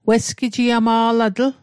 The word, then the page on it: wesgijiama'latl: Mi'gmaq